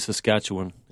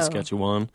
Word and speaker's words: Saskatchewan: And the locals always give me a hard time because it's actually Saskatchewan. It's not Saskatchewan. But it's a better rhyme with Saskatchewan.